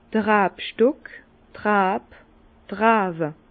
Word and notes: d'r Rabstock, d' Rab, d' Rawe: Bas Rhin; Herrlisheim

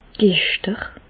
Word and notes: d' Gechter: Alsatian; Bas-Rhin; Reichshoffen

